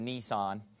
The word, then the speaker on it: Nisan: Chapter 2, verse 1, it came to pass in the month Nisan, or you can pronounce it Nisan,